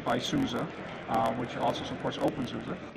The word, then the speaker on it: openSUSE: And you said OpenSUSE, that's the official pronunciation? – Yeah, OpenSUSE. – Once yeah. – and for all? – Once and for all.